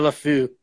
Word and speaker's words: Le Fou: Pierre Le Fou, F-O-U.